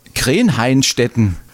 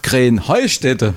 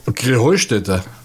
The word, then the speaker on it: Krähenheustätte: Ja, ihr saget immer Krähenheinstetten. Und dabei heißt es doch Krähenheustätte, oder wie? – Krähenheustätte. – Ja, genau. Und das ist die Betonung auf dem Heu.